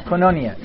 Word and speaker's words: koinonia: Did you say koinonia? Not koinonia, koinonia, okay? K-O-I-N-O-N-I-A, right? Yeah.